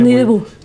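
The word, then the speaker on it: Nuit Debout: Con la Nuit de Bot, ¿no? No sé cómo se pronuncia. – Nuit de Bot. – Nuit de Bot.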